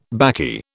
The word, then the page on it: baccy: British English